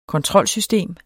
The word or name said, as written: kontrolsystem